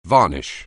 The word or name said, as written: varnish